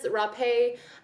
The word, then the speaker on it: rapé: So anyway, that is rapé. You can look it up. It is spelled like an unfortunate word. It is R-A-P-E, but it has a little slanty line thing over the E.